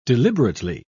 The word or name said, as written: deliberately